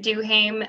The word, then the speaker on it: Duhaime: Or you can find me on Facebook or Instagram if you just search my name, Bridget Duhame. That's B-R-I-D-G-E-T. And last name is spelled D-U-H-A-I-M-E.